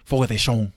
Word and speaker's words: Fogo de Chão: Let me pronounce it right. Fogo de Chão. – There we go.